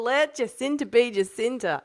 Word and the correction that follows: Jacinda: It's Jacinda, isn't it? – Jacinda, I think it is. Yeah, Jacinda. It's like Australian pronunciation.